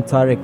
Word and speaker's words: Tarek: Just put in my name, Tariq Yamani. – Spell it to them. – T-A-R-E-K.